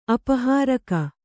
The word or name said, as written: ಅಪಹಾರಕ